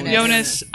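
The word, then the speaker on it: Joonas: Is him Junis or Jonas? – Jonas.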